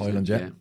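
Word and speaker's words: Højlund: Hoyland, is that how you pronounce it? – Hoyland.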